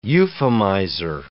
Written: euphemizer

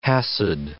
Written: Chasid

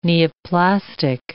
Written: neoplastic

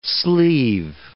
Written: sleeve

